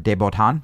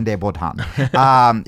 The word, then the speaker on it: de Botton: For the most part, it is just there are certain behaviors, you know, like I always say his name wrong, but Alain DuPont or de Botton. There it is. Mm-hmm. Alain de Botton.